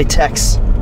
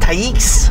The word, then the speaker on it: Taix: Right by Tex. – Got you. – Oh, yeah. The place I never know how to pronounce. Certainly isn't spelled like Tex. – No. – Taix. – Doesn't feel like Tex. – No. Yeah, I have to go Taix.